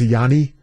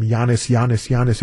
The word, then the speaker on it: Giannis: By the way, do you know that it's actually pronounced Yanni? We discussed that last night on WTMJ Nights. We had a gentleman who was Greek and said you know, it's actually Yanni. The S is silent in Greece.